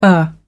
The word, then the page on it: o. henry: American English